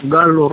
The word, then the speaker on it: Gallo: Guillermo Gastón Galo Rojas, doble L, en Gallo se escribe.